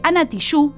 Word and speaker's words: Tijoux: Y así con todo este menjunje o como se diga, de agenda, nos vamos a un tema musical de Ana Tijoux, me dijeron que se pronuncia así, nunca en mi vida lo pronuncié, que va a cantarnos Antipatriarca.